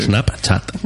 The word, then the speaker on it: Snapchat: Em, hablar de Snapchat, lo he dicho bien. – ¿Lo sí, he sí, sí, Snapchat. – Snapchat. – Está bien dicho.